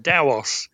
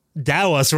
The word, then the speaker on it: TAOS: It's pronounced Daos. – Daos, right. Okay, right. There you go. I pronounced it wrong for 25 years. – T-A-O is a Chinese word, and it's pronounced Dao. It's right. Supposed to be an unaspirated T, so it sounds like a D.